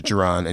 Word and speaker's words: Jaron: My name is Jaron.